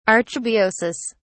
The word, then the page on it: archebiosis: British English